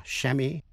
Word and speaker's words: chamois: Chamois là tên một thứ sơn dương mà da mềm của nó dùng để lau xe hơi hay đánh bóng bình bạc. Có thể đọc là chamois hay là chamois. Champagne là rượu sâm banh. Chalet, C-H-A-L-E-T.